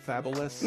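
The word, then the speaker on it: Fabolous: Fabulous? – Mm-hmm.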